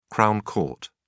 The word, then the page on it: crown court: British English